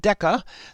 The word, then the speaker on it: DeCA: The Defense Commissary Agency, or it's abbreviated D, small e, capital C, capital A, I'm going to pronounce it DECA,